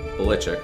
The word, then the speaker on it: Belichick: He did an interview on CBS, and they put dramatic music under it that says, all these years, we've been saying his name wrong. – My dad's name was Stephen Nicholas Belichick. My dad's name was Stephen Nicholas Belichick. – Belichick.